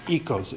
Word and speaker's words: oikos: It looks like oikos, like the yogurt that you get in the store. It's pronounced by modern Greeks as oikos.